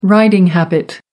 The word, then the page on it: riding habit: American English